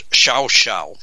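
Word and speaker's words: Llao Llao: There is an incredibly magnificent resort there called Xiao Xiao. And again, it's the funny way they pronounce things down there. It's L-L-A-O, L-L-A-O. How they get Xiao Xiao out of that, I don't know.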